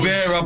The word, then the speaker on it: Riviere: Say it with me, like my surname's like R-I-V-I-E-R. E. It ends Rivera. With an E.